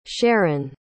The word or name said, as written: sharon